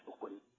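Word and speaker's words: hun (魂): The Chinese pronunciation is hun.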